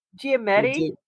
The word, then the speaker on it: Giamatti: Is that did I pronounce that right? Giamatti. – Giamatti.